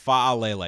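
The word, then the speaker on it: Faalele: I believe it's pronounced Falele because there's two A's and that it's F-A-A.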